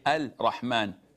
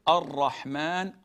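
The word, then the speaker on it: Ar-Rahman (الرحمن): In Ar-Rahman, it is Al-Shamsiyya. We don't say Al-Rahman, Al-Rahim. We say Ar-Rahman, Ar-Rahim. We merge it and we put an emphasis on the R.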